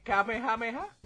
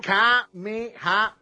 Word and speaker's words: Kamehameha: El famoso Kamehameha. Y yo digo ha, ¿eh? Ah, la H no se pronuncia.